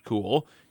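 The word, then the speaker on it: Kuhl: That's K-U-H-L, pronounced Cool, and a nickname of Jibber there.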